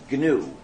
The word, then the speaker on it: GNU: But when it's the name of our system, the correct pronunciation is GNU. Pronounce a hard G.